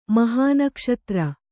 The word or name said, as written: ಮಹಾನಕ್ಷತ್ರ